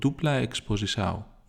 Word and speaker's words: Dupla Exposição: Το κομμάτι που ανοίγει την τελευταία τους δουλειά είναι το Dupla Exposição, νομίζω, δηλαδή έτσι προφέρεται στα πορτογαλικά κάπως.